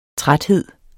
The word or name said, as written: træt